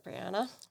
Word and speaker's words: Brianna: I love that, Brianna. Rhymes with banana.